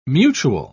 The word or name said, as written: mutual